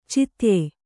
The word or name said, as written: ಛೇದ್ಯ